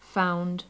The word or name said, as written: found